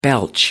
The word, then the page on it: belch: American English